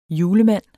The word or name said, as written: julemand